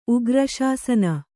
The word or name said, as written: ಉಗ್ರಶಾಸನ